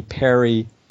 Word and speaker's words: Peary: And then in high school, the book that really affected me was this book called Cult Movies by Danny Perry. The name is P-A-R-Y, but it's pronounced like Perry. It looks like Peary.